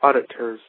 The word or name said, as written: auditors